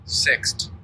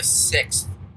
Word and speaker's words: Sixt: S-I-X-T. – Sixt. – Yeah. – It's like the sixth, but without the H. Sixt. – Nice. Yep, reads above a fourth grade level. – I read at a sixth grade level.